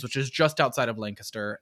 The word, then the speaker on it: Lancaster: The citizens banded together to I pronounced it at a bar and somebody was like, it's Lancaster, just so you know, Lancaster. And I was like, oh.